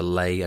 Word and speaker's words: Les: L-E-S is the Lay, and B-I-C-H-E-S is the Biche.